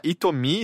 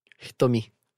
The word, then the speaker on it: Hitomi: deixando claro, é porque se fala Itomi, escreve H-I-T-O-M-I, né? – Isso. Itomi.